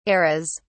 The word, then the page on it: eras: British English